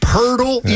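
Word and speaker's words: Poeltl: Pirtle, even though there's no R in his name, it's pronounced Pirtle.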